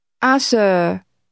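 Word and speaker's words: Ah, sir (阿Sir): Ah, sir. Sir. – Is the Cantonese pronunciation of the English word sir, and the ah beginning is used to draw the person's attention.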